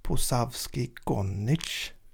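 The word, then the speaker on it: Posavski gonič: Och så har du då en, eh, nu kan inte jag uttala detta, Posavski Gonic. – Precis. Var det rätt? Ja, exakt.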